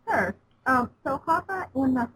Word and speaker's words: Hapa: is it pronounced Hapa? – Yes, yes.